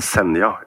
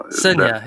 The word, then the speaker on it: Senja: You mean Senja? – Senja, yeah. – Yeah. – Yeah, soft J.